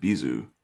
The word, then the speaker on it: B'z: I don't know, but apparently this Bizu, I guess in Japanese you pronounce it Bizu.